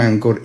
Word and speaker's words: Anchor: Entonces, Anchor se escribe Anchor como de ancla en inglés. Pero se pronuncia Anchor como, Si, como si fuera una angina de pecho, un ancor inestable.